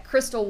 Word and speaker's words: Kristel: You can find us at Crystal Ward TX, but my name is spelled weird, K-R-I-S-T-E-L. Crystal Ward TX on all the major social media platforms.